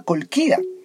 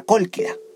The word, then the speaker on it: Cólquida: Resulta que Jasón se casó con Medea, la hija del rey de la Cólquida, o de la Cólquida. Ahí hay un acento y no lo pronuncia.